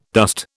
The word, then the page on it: dust: British English